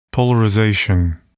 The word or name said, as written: polarization